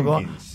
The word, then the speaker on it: Tompkins: Douglas Tompkins, quería pronunciarlo Tompkins.